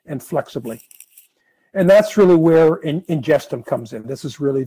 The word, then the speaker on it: Ingestum: So basically what Ingestum is, and you pronounce it Ingestum,